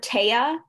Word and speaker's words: Tea: Yeah, we laugh about it, but really it's so sad because both Simran and I pronounced her name like T, T-E-E, instead of Tey-a the whole time.